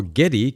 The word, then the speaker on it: Geddy: His stage name, now Geddy, came from the way his mother pronounced Gary with a heavy Polish accent.